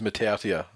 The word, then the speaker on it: Matautia: Apparently, the pronunciation of Matautia is Matautia. That's right. The way, according to a Newcastle Herald article from 2011.